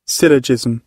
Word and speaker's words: syllogism: So this is how it's pronounced. – Syllogism.